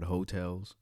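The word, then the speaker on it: Heaux Tales: It's called Hotels. And I think it's spelled like H-H-E-A-U-X, Hotels, T-A-L-E-S.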